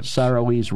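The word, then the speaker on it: Saoirse: Saroise Ronan.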